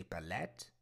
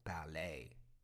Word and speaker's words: ballet: Silent T. Ballet. My sister dances ballet. Don't say ballet. The word is ballet.